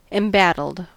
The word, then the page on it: embattled: American English